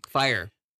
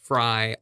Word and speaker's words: Fyre: I think that says Fry also every time I see the word. Because the way that's spelled, but it's actually fire. – F-Y-R-E.